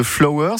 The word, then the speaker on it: FLWR: Fondateur et CEO de Flower, ça s'écrit F-L-W-R, je le précise évidemment, c'est important, distributeurs automatiques de bouquets de fleurs fraîches locales et de saison sur Paris depuis février 2026.